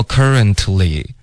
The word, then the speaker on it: currently: 然后 currently 的这个 t 呢，最好省略。 OK，所以其实会发 currently，然后这个 ly 跟 n 之间会有一个很轻的 y 的音，所以不是 in，它是 yen。